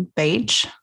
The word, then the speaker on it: Bach: I mean, even the explanation, like, I have always thought of them as Bach remedies, like you said in the book, because it's spelled the same way as the composer. So learning that they were actually Bach remedies, or even Bach, like, depending on how precise you want to be with the local pronunciation, like, that was interesting.